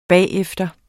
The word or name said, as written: bagefter